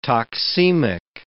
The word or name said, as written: toxemic